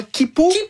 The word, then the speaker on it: Quipu: Et si vous pensiez que c'était déjà vertigineux d'imaginer l'Aniakea, attendez qu'on vous parle de comment Kipu. Ça s'écrit Q-U-I-P-U. – Oui. Donc, Kipu, c'est deux fois plus grand que l'Aniakea.